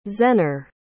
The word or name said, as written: zehner